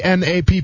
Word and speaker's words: Knapp: And then uh, last one we're gonna do here, and I'm, i think it's how you pronounce it, Andrew Knapp of the Phillies, right? Knapp. – How do you spell that? – K N A P P. – No, I think it's just nap.